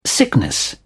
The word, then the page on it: sickness: British English